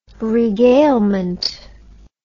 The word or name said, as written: regalement